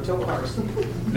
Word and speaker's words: Tokarski: Did I say that right? – No. – Tokarski. – Never, I will never get this right. Sorry, guys. It's right here in front of me, and I still can't pronounce it.